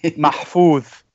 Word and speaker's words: Mahfouz: You were somewhat making a reference to the Egyptian novelist Naguib Maufis. Let's see here. – How would you pronounce that, by the way? – Mahfouz. – Mahfouz. Oh, okay.